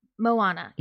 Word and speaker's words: Moana: I don't want to butcher this name, but I was really, the Moana, is that how you pronounce it? – Because, I listened again to Moana. Yes, Moana.